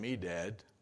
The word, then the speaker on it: Medad: But back in the camp, Joshua found out that there were two men who have great names, by the way, Eldad and Medad. And that is how you pronounce them.